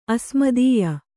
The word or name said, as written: ಅಸ್ಮದೀಯ